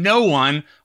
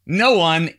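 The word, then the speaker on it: Kno-One: That was a track called The Crawl September from No One, which is spelled K-N-O hyphen one. No One is hip-hop artist Bernard from Tacoma Park,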